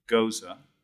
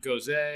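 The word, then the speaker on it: Gose: Before we get to the score, Respira, it's a Goza. – Goza. – We had our first Goza in season two. – It's a beer. It's a style of beer. It's not Goze, Goze. – We tried every pronunciation.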